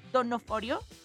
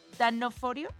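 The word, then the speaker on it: D'Onofrio: Bueno, porque Born Again recupera a Charlie Cox como Matt Murdock y a Vincent Donofrio como Danoforio. Donofrio.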